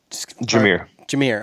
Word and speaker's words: Jahmyr: I don't know how to pronounce Gibbs' first name. – Jahmyr.